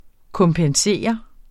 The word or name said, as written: kompensere